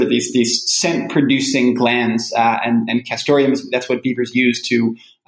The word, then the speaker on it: Castoreum: Oh, yeah. Castoreum. – How do you pronounce it? Castoreum. Okay. – Castoreum. – Yeah. – That's what I say. Other people pronounce it differently. You know, I heard people say castorum without the E. That's how a lot of trappers say it.